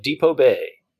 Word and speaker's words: Depoe Bay: tool, tool, through uh, Depoe Bay. Am I pronouncing mm-hmm. that right? – Yes.